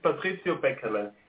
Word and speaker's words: Patrizio Beckerle: In German, it's Patrizio Beckerle.